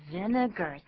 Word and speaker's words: vinegar: But 这个字就是念作 vinegar，三个音节，然后 ar 也不要念作 r，是 er 就好了。